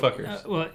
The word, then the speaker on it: NFKRZ: It's like, No Fuckers. – NFKRZ. – Yeah, No Fuckers.